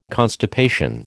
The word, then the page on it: constipation: American English